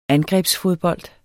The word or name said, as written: angrebsfodbold